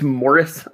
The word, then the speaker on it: Maurice: And I think it's Morris. I think it's like, you know, Maurice is sometimes pronounced Morris. – British pronunciation.